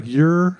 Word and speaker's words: Ouray: I never pronounce it right. – It's like Ouray.